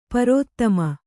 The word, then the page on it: ಪ್ರಥಮೆ: prathame